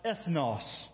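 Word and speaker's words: ethnos (ἔθνος): The Greek word is pronounced ethnos. Kind of sounds like an English word that we have, doesn't it? Ethnic. Ethnicity. The actual word in the Greek refers to other people, groups of people.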